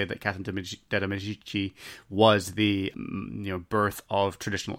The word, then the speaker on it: Medici: De Medici.